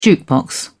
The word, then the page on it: jukebox: British English